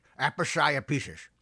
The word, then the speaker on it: aposiopesis: You see, there's this word, aposiopesis. The first syllable is app, and it means the sudden breaking off in mid-sentence as if the speaker were unwilling or unable to continue.